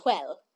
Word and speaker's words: Hwel: H-W-E-L? How did you pronounce it in your head? – H-W-E-L. Yeah. Quell.